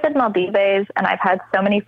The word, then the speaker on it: Maldives: Maldives. – Maldives.